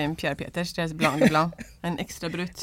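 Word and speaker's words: Blanc de Blancs: Och jag är jättedålig på att uttala namn så, men Pierre Peters Blanc de Blanc, eller hur? – Ja, Blanc de Blanc.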